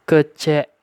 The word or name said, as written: Kecék